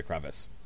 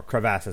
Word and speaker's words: crevasse: Okay, so at the end of this episode, this is where they fall into the crevice, or crevasse, as it's pronounced. So this is a good episode.